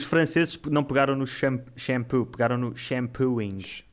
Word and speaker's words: shampooing: È buonissimo, Avec le G, G muet. – Ok, ok. – Bello. – Le G muet. Ah, fica muet. Une lettre muette. Shampooing. Alors que d'habitude, les mots anglais, on prononce toutes les lettres, même en français.